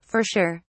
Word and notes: for sure: British English

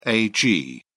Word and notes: ag: British English

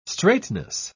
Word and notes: straightness: British English

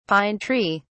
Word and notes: pine tree: British English

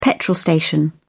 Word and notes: petrol station: British English

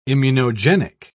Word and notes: immunogenic: British English

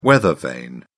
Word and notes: weathervane: British English

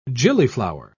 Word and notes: gillyflower: British English

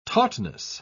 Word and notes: tautness: British English

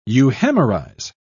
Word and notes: euhemerize: British English

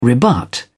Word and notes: rebut: British English

